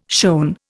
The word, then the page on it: shown: Indian